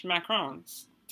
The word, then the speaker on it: macaron: And today we're talking about French macarons. Did I pronounce that right? – You did. That was good.